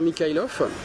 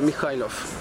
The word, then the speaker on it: Mikhailov: Alors, en fait, en français, tu le prononces Pierre Mikhailov. Mikhailov? Voilà. Mais en russe, sinon, tu dis Piotr Mikhailov.